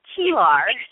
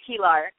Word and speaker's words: Keillor: It's Keillor. It's not pronounced the way it's spelled, right? Keillor.